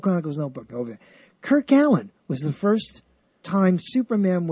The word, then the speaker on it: Allyn: And that's spelled, I know I remember it because it was always spelled weirdly. A-L-L-Y-N, Allen. Kirk Allen.